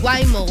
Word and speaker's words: Waymo: Ah, el Wimo, el Wimo. – Guaymo, Guaymo. – Ah, Guaymo, Guaymo, Guaymo. Ah, no es el Wimo. – No sé si se pronuncie así. – Parece un Wimo, anyway. – Pues sí, exacto. Se escribe W-A-Y-M-O, los Guaymo. Que yo la primera vez que los vi fue en Phoenix, hace como tres, cuatro años que fui, ¿te acuerdas? – No, sí.